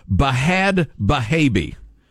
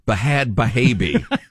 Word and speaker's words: Bhad Bhabie: She dubbed herself Bahad Bahabi. You know me, I don't like silent letters. It's B-H-A-D B-H-A-B-I-E. Bahad Bahabi.